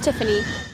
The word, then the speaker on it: Tiffany: My name's Tiffany. – How do you spell it? – T-I-F-F-A-N-Y.